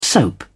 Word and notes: soap: British English

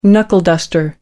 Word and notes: knuckleduster: American English

